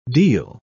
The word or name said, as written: deal